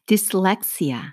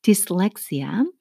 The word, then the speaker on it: dyslexia: 난독증은 디슬렉시아, 어, 디스렉시아라고 읽어요. 아, 난독증. 조금 단어가 좀 이상해 보이죠. 뭔가가 디스 하면 dis 같은데 dys로 시작을 해요.